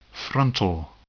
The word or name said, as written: frontal